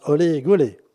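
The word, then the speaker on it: Hully Gully: Il s'agit de la hooligouli que l'on prononce olé goulé et qui tient du Madison, de la country et qui se danse en ligne.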